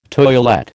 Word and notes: toilette: American English

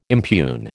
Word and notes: impugn: American English

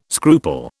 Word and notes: scruple: British English

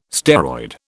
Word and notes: steroid: British English

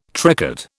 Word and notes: tricot: British English

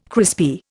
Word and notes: crispy: Indian

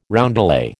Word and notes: roundelay: American English

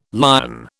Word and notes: Lyon: British English

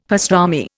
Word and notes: pastrami: Indian

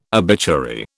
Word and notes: obituary: British English

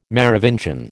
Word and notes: Merovingian: American English

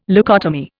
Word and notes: leucotomy: Indian

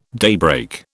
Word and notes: daybreak: British English

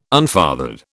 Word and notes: unfathered: British English

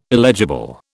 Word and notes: illegible: British English

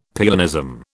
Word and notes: pianism: British English

